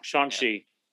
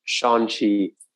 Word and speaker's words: Shang-Chi: Shang-Chi. Say it for him again. Say it for him again. – Shang-Chi.